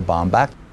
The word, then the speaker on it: Baumbach: Noah Baumbach.